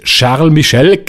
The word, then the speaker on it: Charles Michel: Haben sie in der vergangenen Nacht abgenickt, wie erwartet, aber EU-Ratspräsident Charles Michel, man hat mir aufgeschrieben, Klammer auf, sprich Charles Michel, genauso wie ich ihn ausgesprochen habe, ohne gelesen zu haben, wie ich ihn aussprechen muss, der sagt jetzt Folgendes.